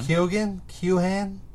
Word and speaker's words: Keoghan: Barry Keoghan. I think that's how you say his name. Uh-huh. Keoghan? – I think you're right.